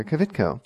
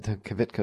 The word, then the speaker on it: Kvitko: And if you want to follow me on Twitter, it's at Dr. Kvitko. And then I've been reminded on occasion that Kvitko is not exactly the easiest word to spell, name to spell.